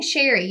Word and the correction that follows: Sheree: You know why? – Why? – Because in my other office, it was a Sherry spelled like that. It's Sheree.